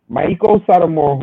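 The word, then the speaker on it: Meiko Satomura: Maiko Satomura, who is the UK champion, and out of nowhere, Blair Davenport throws her motherfucking self into the mix.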